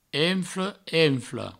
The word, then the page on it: enfle: enfla; enflo